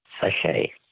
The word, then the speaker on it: sachet: Sachet. – A sachet. S-A-C-H-E-T. Well, they were too easy for you, so we're not asking you any more words that end with a silent T, mainly because there are no more questions in that category.